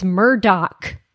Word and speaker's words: Murdaugh: And also, other than all of the murders, the weirdest thing about the family is that the name is spelled Murdaugh. – Should be Murdaugh, but it's Murdoch.